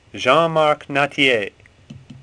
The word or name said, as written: Nattier